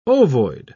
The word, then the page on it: ovoid: British English